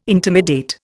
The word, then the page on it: intimidate: Indian